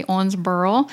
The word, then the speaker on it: Owensboro: That's spelled Owensboro, but you say Owensboro.